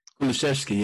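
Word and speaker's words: Kulusevski: The guy who plays for Juventus, the right winger, Kluwanski. – Kulishevsky, yeah. Kulishevsky. – Yeah. – He was good. – Pronounced it perfect.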